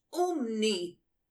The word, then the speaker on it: Umni: Mrs. Umi has fainted. – Madam, it's pronounced Umni, with an N.